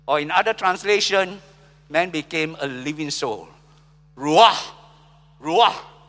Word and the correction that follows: Ruach: Or rather, it's pronounced with a guttural sound. It's so hard for me to pronounce that. Ruach. That sounds more like it.